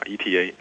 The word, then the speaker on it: Eta: 是宝瓶座 Eta 流星雨。 – Eta，对 Eta，它那个像希腊字母里头有个像 N，然后右边的尾巴长长一点的啊，念成 Eta Eta Eta。